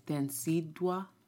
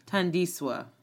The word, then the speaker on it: Thandiswa: Densidwa. Thandiswa. Thandiswa? Yeah. Thandiswa.